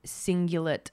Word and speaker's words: cingulate: Let me try and pronounce this without butchering it because I can remember it's the anterior mid cingular cortex. Let me see if I got that right. AMCC. The anterior mid cingulate cortex. Okay, close he said this is the area of the brain that is linked to willpower.